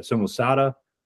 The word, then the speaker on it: Samosata: Lucian of Samosata, or how do you say that? Samosata? Mm-hmm.